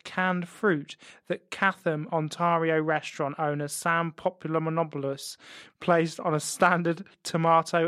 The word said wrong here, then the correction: Panopoulos: I should pronounce his name properly. It's Panopoulos. Panopoulos. He's Greek.